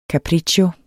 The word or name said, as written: capriccio